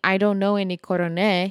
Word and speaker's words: coroné: Coroné is a regional pronunciation of the word coronel or colonel in English. Many